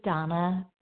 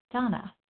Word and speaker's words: Dana: This is Donna B., recovered compulsive overeater and bulimic, calling from Denver. And my name is spelled D-A-N-A, but pronounced Donna.